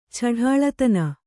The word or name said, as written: ಛಢಾಳತನ